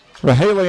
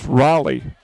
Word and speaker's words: Rahaley: Rahaley Anderson. – Looks like 54, Keziah Booth in on the tackle. – Pronounced Raleigh. Raleigh Anderson.